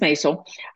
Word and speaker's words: Maisel: I say it wrong all the time. It's Mrs. Maisel.